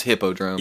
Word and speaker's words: Hippodrome: That was All About the Tones with Earthy Hippodrome or Hypodrome. We're not sure how to pronounce it. – It's Hippodrome, yeah. Hyperdrome would be with a Y.